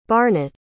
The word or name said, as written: barnet